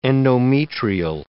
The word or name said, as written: endometrial